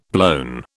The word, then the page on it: blown: British English